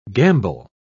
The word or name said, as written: gamble